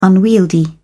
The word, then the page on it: unwieldy: British English